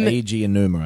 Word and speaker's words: Aonuma: Oh, Aonuma, I think. – Aonuma, yeah. Him. – Eiji Aonuma, I believe, yeah, is how you say it.